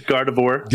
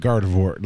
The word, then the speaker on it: Gardevoir: I look at Garvador or whatever her name is. I never say it right, but I know who that is. – Gardevoir. – Yeah. It's like God of War, but Gardevoir.